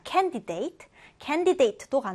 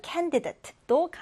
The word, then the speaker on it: candidate: 이번에는 첫 음절에 1강세를 두고요. 자, 이거는 발음이 두 가지 가능하네요. candidate. 자, candidate도 가능하고 또는 candidate도 가능한데요.